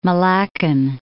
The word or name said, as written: Malaccan